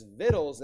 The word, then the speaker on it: victuals: It looks like victuals, okay, or victuals. That's victuals. That means food, okay? So that's the proper way to pronounce that.